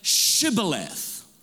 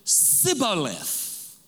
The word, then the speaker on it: Shibboleth: And he would say Sibboleth, for he could not pronounce it right.